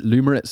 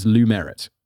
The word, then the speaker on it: Lumerit: I don't know if it's just my British pronunciation, but I want to pronounce it Lumerit so bad, but it's Lumerit because there's like a merit and it's got to do with academia.